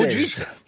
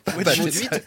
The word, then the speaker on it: Mauduit: Ah, vous le prononcez bien. – L'idole du petit-déj. Maud-huit. – Maud-huit.